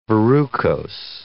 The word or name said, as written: verrucose